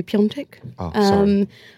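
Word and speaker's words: Piątek: It's weird because it's written Piatek, but it's pronounced supposedly Piontek.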